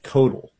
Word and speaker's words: Koeltl: One quick thing, Emmanuel, the judge that you mentioned, is it spelled K-O-E-L-T-L? – Yeah, I was kind of hard-pressed how to pronounce that. – Yeah, that's a weird one. That's Judge Codal, actually.